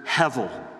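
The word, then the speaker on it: hevel (הבל): Well, the word that's translated in our English translations as vanity comes from the Hebrew word hevel. And that's spelled in Hebrew, H-E-B-E-L. The B is pronounced like a V.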